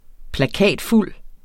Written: plakatfuld

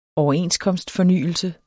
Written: overenskomstfornyelse

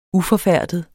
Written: uforfærdet

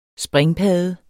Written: springpadde

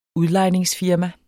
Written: udlejningsfirma

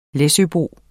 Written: læsøbo

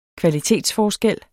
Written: kvalitetsforskel

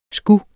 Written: sgu

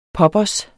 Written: poppers

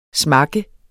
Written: smakke

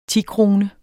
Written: tikrone

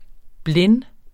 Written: blind

